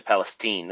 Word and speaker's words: Palestine: But they all once they did in East Palestine or at Palestine, it's actually Palestine. That's how you say it up there, even though it's spelled like Palestine.